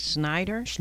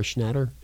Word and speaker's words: Schnatter: I think it's Snyder. – S-C-H-N-A-T-T-E-R, Schnatter. Is that how you say it? – I don't know. It seems like it is Schnatter.